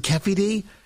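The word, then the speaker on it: Keffe D: Notorious Keffi, I think he pronounces it Keffi D.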